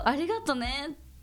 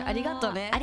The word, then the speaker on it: ありがとうね: ありがとうね。 You say it like that. ありがとう is the right. Yeah. When you say ありがとうね. – ありがとうね。 – ありがとうね。 – ありがとうね。 Oh, so yeah. – ga has the accent.